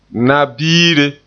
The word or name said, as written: na-biire